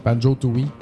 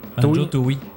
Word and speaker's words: Banjo-Tooie: Alexandre Armon qui dit Banjo-Kazooie et mention spéciale pour, je pourrais le prononcer, Toi, T-O-I-E. – Oui, Banjo-Tooie. – Banjo-Tooie.